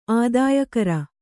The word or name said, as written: ಆದಾಯಕರ